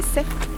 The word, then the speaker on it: Saix: S-A-I-X, qui se prononce l'arête des sexes.